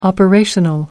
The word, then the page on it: operational: American English